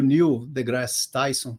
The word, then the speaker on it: Neil deGrasse Tyson: É, na semana passada, eu tava, eu, eu abri o YouTube, cara, e apareceu um vídeo do, do Neil deGrasse Tyson, eu acho que é assim que pronuncia, né? Sim. O nome dele.